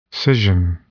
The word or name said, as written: scission